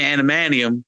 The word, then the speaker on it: adamantium: Like, at this point, I'm like, why would you go through that pain of getting the adamantium when you know your healing factor is better without the adamantium? Am I saying it right? Adamantium. Because it's adamantium. – Yes.